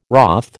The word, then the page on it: wroth: American English